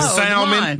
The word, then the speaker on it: salmon: Salmon. That's salmon. – Salmon has that, well, that L is supposed to be silent, ain't it? – Kind of, sort of.